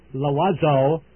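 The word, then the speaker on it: Loizeaux: That is the Loazo Publishers. Now, I will spell the name Loazo for you, since it is rather difficult and unusual. It is L-O-I-Z, like in zebra, E-A-U-X, pronounced Loazo Brothers, Corlies, that's C-O-R-L-I-E-S, Corlies Avenue, or Box 277 in Neptune, New Jersey. That's N-E-P-T-U-N-E, Neptune, New Jersey.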